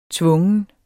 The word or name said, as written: tvungen